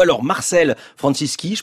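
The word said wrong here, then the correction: Francisci: je prononce bien ? – Marcel Franciszi.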